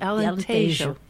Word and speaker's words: Alentejo: We talked a little bit about Alentejo. You pronounce the J.